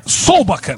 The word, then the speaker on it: Solbakken: É o Solbak, ou Solbeck, eu não lembro a pronúncia correta, acho que é Solbak, né? – É, Solbakken.